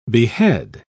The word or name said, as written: behead